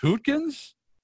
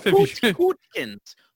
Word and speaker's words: Hootkins: William, I believe his name is pronounced Hootkins. So you might not know. I don't know if Hootkins.